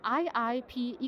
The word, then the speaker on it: IIP: Genau, also Christina Bodrasic-Bönic und jetzt gerade vor mir von IIP Ecosphere. War das richtig, das Englisch auszusprechen? – Das ist richtig. Intelligent Industrial Production ist IIP und von dem her darf man das durchaus Englisch aussprechen.